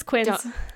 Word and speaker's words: quince: So, quince or quince. I don't know how to say it. – It's quince. – Is it quince? – Yeah, like the fruit thing? – Yeah.